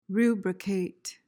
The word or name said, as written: rubricate